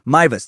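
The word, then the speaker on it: Meiwes: During the trial of one German cannibal that we'll cover in a second, a guy named Armin Mews, or I think it's I had their pronunciation here in a second. Mivas, there we go.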